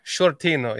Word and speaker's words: Sciortino: And I am joined today by a special guest, Dan Shorty Sciortino. Did I get the name right? – Shortino, yes. – Shortino, okay.